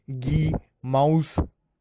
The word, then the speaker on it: Gemüse: Gemüse. Can you listen it? Gemüse. This pronunciation is familiar to the word Gemüse, Gemüse. So now you can imagine ghee as well as you can imagine mouse, and even you can imagine vegetable.